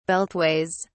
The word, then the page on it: beltways: British English